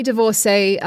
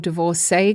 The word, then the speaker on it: divorcee: So The Gay Divorcee, I don't know if it's divorcee or divorcee because I've heard people say it both ways. I'm going to say divorcee because it rhymes with gay.